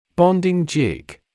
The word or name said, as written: bonding jig